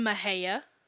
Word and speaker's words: Mexia: You mean Mexia? – Yeah. Mexia. So obviously it is spelled M-E-X-I-A, but it is pronounced Mejia. Some notable people from Mejia are Les Baxter, a musician and composer.